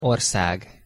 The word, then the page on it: orszag: Hungarian